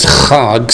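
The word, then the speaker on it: chag (חג): In Hebrew, the word feast is chag, C H A G, if you want to spell it with English letters.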